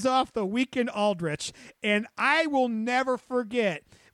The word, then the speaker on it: Aldrich: It was interesting to see Tony go after Aldrich. I said it right this time. – You did.